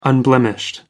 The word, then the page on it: unblemished: American English